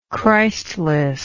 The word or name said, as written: Christless